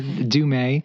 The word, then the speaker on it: Dumais: I'm Gary Dumay, last name pronounced like the month of May, so it's easy to remember, Dumay.